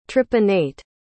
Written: tripinnate

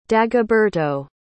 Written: dagoberto

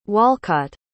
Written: Walcott

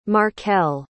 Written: markell

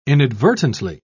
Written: inadvertently

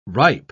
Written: ripe

